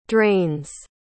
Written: drains

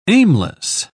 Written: aimless

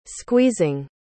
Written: squeezing

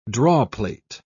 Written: drawplate

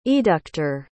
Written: eductor

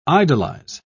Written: idolize

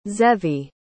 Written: zevi

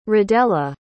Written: radella

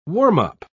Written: warm-up

